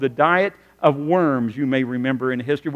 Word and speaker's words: Worms: But it's really pronounced the Diet of Worms. In Worms, Germany, the W is pronounced like the V.